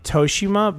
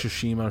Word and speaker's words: Tsushima: I say Toshima, but I think it's Tosh...